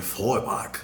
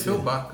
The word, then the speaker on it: Feuerbach: Eu joguei no, no Google e ele me dá uma, uma, pronúncia bem estranha, bem alemão mesmo, né? Que é Feuerbach. – Você sempre parece... Feuerbach, né? Porque a gente é português, a gente é brasileiro, a gente tem que aportuguesar as coisas.